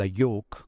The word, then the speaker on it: York: da York, come dicono loro che non pronunciano la R, e dopo averlo sconfitto ha sottomesso gli inglesi, ma nella pedagogia nazionale d'oltremani che è l'inizio della gloria nazionale.